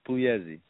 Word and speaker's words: Pugliese: It's Pugliese. The G is silent.